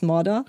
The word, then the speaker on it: Mordor: Wir haben halt diese Tüte aufgemacht und daraus kam halt der Geruch von Mordor. Heißt es Mordor? – Ja.